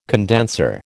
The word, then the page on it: condenser: American English